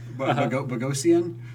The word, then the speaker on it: Bogosian: But Eric Bogosian. Bogosian? – Bogosian.